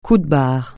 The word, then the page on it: coup de barre: francais